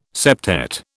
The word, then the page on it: septet: British English